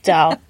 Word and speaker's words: dal: One word, one syllable, dal. Nothing dull about dal.